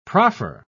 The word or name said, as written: proffer